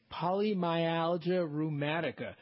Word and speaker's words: Polymyalgia rheumatica: It's polyarthritic rheumatica. – Yeah, easy for you to say. Polymyalgia rheumatica.